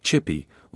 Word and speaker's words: CHPE: The new energy source, the Champlain-Hudson Power Express, or CHPE, pronounced Chippy, was scheduled to start serving New York City at full force on July 1.